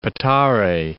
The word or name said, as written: Petare